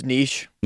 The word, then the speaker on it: niche: How do you pronounce that word? – I think it's niche. – Yeah, niche sport.